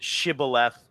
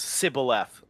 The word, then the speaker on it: shibboleth: the Hebrews pronounced it shibboleth, and because the way that it was pronounced by the Philistines, it was sibboleth.